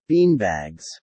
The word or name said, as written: beanbags